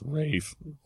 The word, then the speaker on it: Ralph: Ralph. – What's it? He says Rafe. Look, man. – Rafe. – It's Ralph, okay? I refuse to do that.